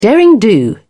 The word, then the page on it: derring-do: British English